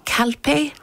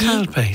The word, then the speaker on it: Calpe: Calpe, C-A-L-P-E. – Calpe, it's in Leitrim, I think.